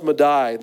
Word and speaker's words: Madai: of persia and this looks like i know it looks like media but uh, it's pronounced Madai.